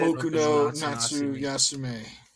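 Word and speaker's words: Boku no Natsuyasumi: Boku no Natsu Yasume.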